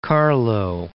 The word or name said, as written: Carlow